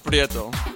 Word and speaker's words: Prieto: Prieto. – Okay, you got roll the R.